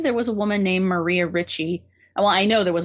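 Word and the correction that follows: Reiche: And I think the pronunciation, she was German, so it's Maria Reicha.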